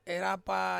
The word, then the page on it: rapa: Ansus